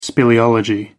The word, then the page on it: speleology: American English